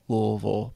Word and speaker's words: Louisville: And that was near Louisville or Louisville or Louisville. That's the proper way to say it. Louisville, by the way.